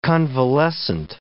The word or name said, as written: convalescent